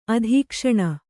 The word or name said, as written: ಅಧೀಕ್ಷಣ